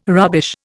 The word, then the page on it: rubbish: Indian